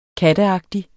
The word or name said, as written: katteagtig